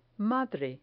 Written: madre

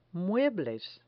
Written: muebles

